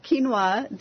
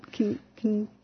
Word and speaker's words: quinoa: Yeah, well, I don't know whether it's maybe, your Canadian accent, but we pronounced quinoa differently. How did you say it? – Quinoa.